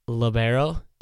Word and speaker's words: libero: Now, Katie, you're the libero. Is that the right way to say that? – Yeah.